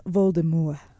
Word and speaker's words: Voldemort: And it's pronounced Voldemort. – Oh, is it? – I never knew that. – JK said that on her Twitter. – Nice. Right. All right. – French.